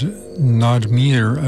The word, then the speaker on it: Naadmir: We heard Nadmir, I believe it's pronounced, N-A-A-D-M-I-R, from an album of some years back on Project Records called Phase 9.